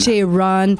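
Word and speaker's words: J-Ron: Si vous le connaissez pas, il faut le googler, ça s'écrit J. Trait d'union, R-O-N, Jaron.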